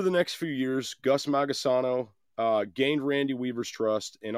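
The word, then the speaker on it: Magisono: This man was Gus Magasano. All right. Gus Magasano. I could be saying that wrong, but I'm pretty sure it's Magasano. He was a paid ATF informant.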